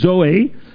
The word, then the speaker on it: Zoe: It is pronounced Zoe.